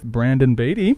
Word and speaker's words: Brandon Baity: We are here today with Brandon Baity.